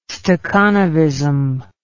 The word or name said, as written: stakhanovism